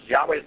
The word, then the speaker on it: Yahweh: Yes, Yahweh is the proper pronunciation. Jehovah is taking the name Adonai that the Jews use in place of it and taking the vowels and pronouncing it.